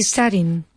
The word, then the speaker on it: studying: What is he studying? – Repeat. – What is he studying? – No. Studying has three syllables.